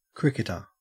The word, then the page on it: cricketer: Australian English